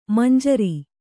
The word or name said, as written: ಮಂಜರಿ